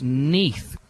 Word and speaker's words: Neath: And also, Dominic, why can't you pronounce it? It's Neath.